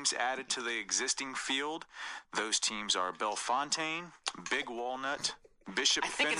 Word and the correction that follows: Bellefontaine: I think it's Bellefountain, bro.